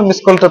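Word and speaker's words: মিসকাল (مثقال): মিস কাল উচ্চারণটা এরকম হবে আর কি মিস কাল মিম তা কফালে ফ্লাম মিসকাল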